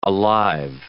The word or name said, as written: alive